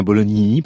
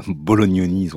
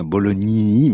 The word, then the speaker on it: Bolognini: Je dis bien Bolognini parce que je reçois des mails d'auditeurs qui se plaignent de ma prononciation Bolognoni. Ils ont raison d'ailleurs, c'est bien Bolognini, mais c'est un petit peu plus difficile à dire.